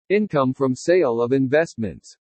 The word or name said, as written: INCOME FROM SALE OF INVESTMENTS